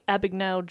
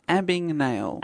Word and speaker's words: Abagnale: Have you heard of the work of Frank Abagnale Jr.? – Oh, that vaguely rings a bell. – Frank Abagnale. – Abagnale. – Abig. – Abag. A-B-A-G. – Abagnale. – N-A-L-E.